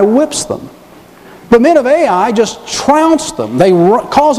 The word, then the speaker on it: Ai: In fact, that's how it's pronounced and that's how it's spelled, Ai.